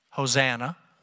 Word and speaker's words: Hosanna: The Greek word here is pronounced, are you ready for this? Hosanna.